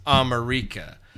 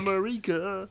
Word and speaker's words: Amerika: It's spelled like America, but it's pronounced Amerika. And I was like, Amerika.